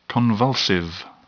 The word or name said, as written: convulsive